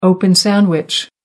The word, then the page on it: open sandwich: American English